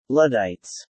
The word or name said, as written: luddites